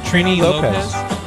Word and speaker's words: Trini Lopez: Trini Lopez.